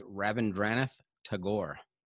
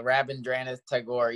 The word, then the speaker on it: Rabindranath Tagore: And I think the pronunciation is Rabindranath Tagore. – Yeah, well, Rabindranath Tagore, easier said than done.